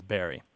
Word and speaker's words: Bury: Most people, it's something like 99% of people in the English-speaking world pronounce it as Barry.